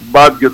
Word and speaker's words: Μπάντιο: αργά τις 12 ώρα περίπου έφτασε ο Μπράκου Μπάντιο, Μπάντιο το επώνυμο, όπως το προφέρει και ο ίδιος,